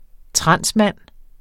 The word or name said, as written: transmand